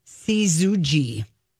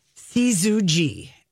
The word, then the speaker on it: syzygy: it's pronounced Syzygy. – Spell it. – Syzygy.